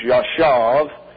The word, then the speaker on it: yashav: If there's no dot, it's pronounced as a V. So it's Yashav.